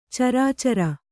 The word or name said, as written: ಚರ್ಚರಿ